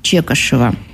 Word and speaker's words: Чекашев: Из личного архива Владимира Чекашева. Участника, правильно говорю? – Да. – Участника тех событий. – Чекашев. – Да, правильное ударение на фамилию.